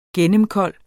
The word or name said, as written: gennemkold